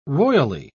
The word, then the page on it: royally: British English